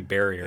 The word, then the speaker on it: Varia: So the Varia suit, is that how it's pronounced? I'm terrible at some pronunciations. – I think Varia. It's supposed to be Barrier.